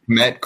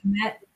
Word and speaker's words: Kmet: I guess give me Cole Kemp. How do I say that? – Kmet, Cole Kmet. Kmet.